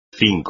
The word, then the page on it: cinco: espagnol